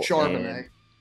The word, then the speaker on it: Charbonnet: And for the Seahawks, DK, Phil Haynes, Evan Brown, Zach Cabernet are all questionable. – Charbonnet.